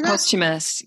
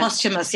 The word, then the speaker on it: posthumous: Posthumous? How do you say that? – Posthumous, yeah. – Posthumous, yeah.